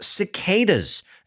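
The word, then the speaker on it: CDK8s: Now, CDK 8S is of course pronounced cicadas, except you have to listen to and about it way more frequently than once every 13 years.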